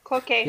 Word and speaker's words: Cloquet: Yep, Cloquet.